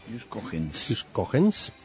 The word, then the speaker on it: Ius Cogens: Ius Cogens, ¿cómo se dice esto? – Ius Cogens.